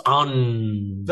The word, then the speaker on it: onn: Um, it's pronounced un.